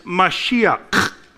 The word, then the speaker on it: Mashiach: And the way that you pronounce that is Mashiach. And if you don't sound like you're hawking up something, you don't say it right.